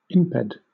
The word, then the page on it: imped: British English